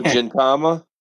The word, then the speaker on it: Gintama: Oh, Gintama? – Yeah. – Yeah. – Oh, is it a hard G? – Is it a white-haired dude?